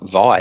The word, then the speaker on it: Vi: He was called, like, his name's Vi, and he's apparently school teacher couldn't pronounce it, like, Vi, it's not hard, it's one syllable, champ.